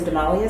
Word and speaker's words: Damali: So Damali is spelled D-A-M-A-L-I.